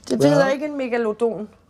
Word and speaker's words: megalodon: Jeg plejer at bruge de latinske udtaleregler. Mm-hmm. Så hedder den megalodon.